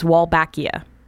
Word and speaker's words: Wolbachia: And the bacteria I'm talking about, and I'm going to totally butcher the pronunciation, I'm sure, is Wolbachia.